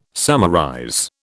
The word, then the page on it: summarize: British English